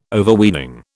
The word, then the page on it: Overweening: British English